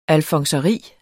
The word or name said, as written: alfonseri